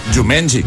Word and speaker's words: Jumanji: Te has quedado, os habéis quedado con lo bien que pronuncia Jumanji. O sea, yo he dicho toda la vida Jumanji y como había dicho. – Con la A muy abierta, además.